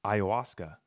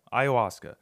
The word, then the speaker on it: ayahuasca: So I'm hearing about how psilocybin mushrooms, and I guess also like ayahuasca. Ah, there we go. Ayahuasca. I don't know why I always want to like try to pronounce the H. Like ayahuasca. Ayahuasca.